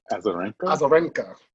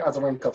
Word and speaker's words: Azarenka: Azarenka. – Yeah, Victoria Azarenka from Belarus.